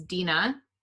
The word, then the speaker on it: Dina: The second time after I correct them, usually I'm called Deanna, Dinah, whatever, like Deanna, it doesn't matter.